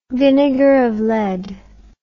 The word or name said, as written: vinegar of lead